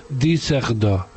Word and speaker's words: Düsseldorf: Es que Düsseldorf lleva la Düsseldorf, que se escribe, lleva dos puntos en la U. Y entonces se dice Düsseldorf.